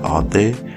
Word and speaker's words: alté: And the accent in alté, you know, they got the accent aigu. You see, I'm taking French. You see, I know what I'm talking about. The accent aigu. So when pronounced, it's pronounced like alté, which is a pidgin abbreviation for authentic.